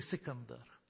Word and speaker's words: Sikandar: Now, Sikandar is the Hindi name for Alexander. The European pronunciation is Alexander. We say Sikandar.